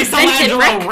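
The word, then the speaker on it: Solangelo: How do you say Celangelo? – That's exactly how you say Celangelo, Rick.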